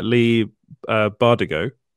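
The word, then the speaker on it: Leigh Bardugo: So I read the first in the trilogy by, I hope I pronounced the name right, Lee Bardugo. – Yeah. – Is that right? – Yeah.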